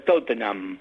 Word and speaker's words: Tottenham: Y basta con el Tottenham, no se atraganten con la J de esa de Tottenham, porque en inglés se pronuncia Tottenham.